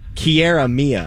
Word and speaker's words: Kiara Mia: All right, from Kalia Mia, am I saying that right? Kalia Mia? – No, all it's, right. Hold on one second. – Now I'm merging this in my head with Mia Khalifa. – Kiara Mia.